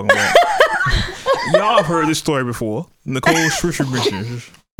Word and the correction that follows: Scherzinger: Scherzinger. – Okay. That sounds good. So whenever it's time to say her name, we're pointing to you. So look, Nicole Scherzinger.